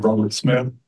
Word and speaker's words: Robert Smith: Robert Smith, I can spell it if you'd like. – I always ask everybody to, so I'm going to ask you to go ahead. – R-O-B-E-R-T-S-M-I-T-H.